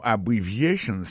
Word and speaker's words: abbreviations: Accent tonique qui vient pour nous prononcer mot yo n'a pour un mot abbreviations nan qui signifie abréviation abbreviations accent tonique là tombé sous troisième syllabe